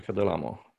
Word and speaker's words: Delamo: Yes, Sergio Delamo. – Oh, you want to say, Delamo. Oh, okay. So it was a pronunciation thing again. I was an American who puts the accent on the wrong syllable, you know, very typical.